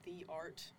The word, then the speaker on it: Theart: My last name is spelled the art, literally like the art.